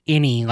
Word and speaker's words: Enyi: E-N-Y-I, pronounced Innie, like the belly button, Innie.